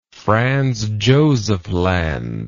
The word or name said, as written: Franz Josef Land